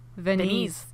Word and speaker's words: Venise: Venise.